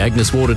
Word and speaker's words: Agnes Water: Down to Agnes Waters? – Mate, it's pronounced Agnes Water.